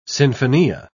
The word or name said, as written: sinfonia